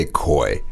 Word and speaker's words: koi: We say koi. – Koi, K-O-I. – Right. – Koi 在中国文化里面，日本文化，它都是象征财富啊，然后吉祥。 – So is that like a Chinese New Year? Is that the fish that's everywhere? – 就有些中国南方不是会做那个年糕嘛，就做成一个 koi shape.